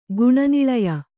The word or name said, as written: ಗುಣನಿಳಯೆ